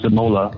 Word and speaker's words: De Molen: They actually just, as De Molen did, De Molen, which is pronounced De Mola in Dutch, my wife's from there, so I speak some Dutch.